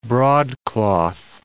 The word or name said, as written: broadcloth